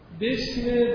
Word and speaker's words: بسم: الف بسم وقتی با کلمه یا حرف قبلی خونده بشه در تلفظ ساقط میشه، یعنی به اسم شما نمیدید، الف میفتد، میگید بسم، این میشه الف بسم. یعنی اگه شما با کلمه قبلش خوندی این الف تلفظ نمیشه، الف اسم، الف بسم.